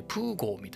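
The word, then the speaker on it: Peugeot: 僕の聞き間違いかもしれないですけど、プジョーじゃなくてプゴー、プーゴーみたいな発音してましたね。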